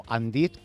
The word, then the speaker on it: Indeed: Y sin duda el más conocido es Indeed, o Indeed, como prefieras pronunciarlo. Pues bien,